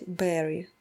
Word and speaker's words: bury: Это слово «хоронить», которое пишется как B-U-R-Y, и хочется его прочитать «bury», но нужно его читать «berry», как ягода.